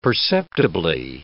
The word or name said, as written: perceptibly